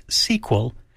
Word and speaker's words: SQL: Known as SQL and pronounced SQL, the acronym stands for Structured Query Language.